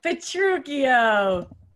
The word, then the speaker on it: Petruchio: Petruchio. – Like Taming of the Shrew.